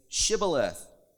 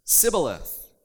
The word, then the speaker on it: Shibboleth: And they said to him, 'Then say, Shibboleth.' And he would say, 'Sibboleth.' For he could not pronounce it the right way."